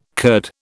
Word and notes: curd: British English

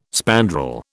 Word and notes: spandrel: British English

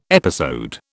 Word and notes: episode: British English

